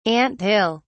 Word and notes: ant hill: British English

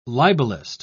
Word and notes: libelist: British English

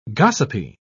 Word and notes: gossipy: British English

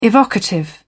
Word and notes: evocative: British English

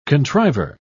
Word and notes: contriver: British English

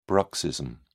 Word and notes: bruxism: British English

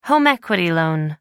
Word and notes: home equity loan: American English

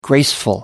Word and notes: graceful: American English

